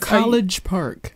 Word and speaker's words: College Park: You said the K. – What is Collet Park. – There you go.